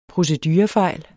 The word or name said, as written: procedurefejl